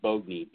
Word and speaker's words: Boagni: or actually Ken Boagney is how it's pronounced. Everybody calls it Boagney, but it's actually Boagney, B-O-A-G-N-I, Ken Boagney.